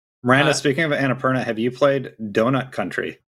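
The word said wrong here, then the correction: Donut County: County, you're right.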